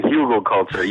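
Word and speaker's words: Hügelkultur: I know it's a German word. Hugelkuter? – Mm-hmm. Did I nail Close. it? Yeah. yeah. Uh, Hugelkultur, yes, real close.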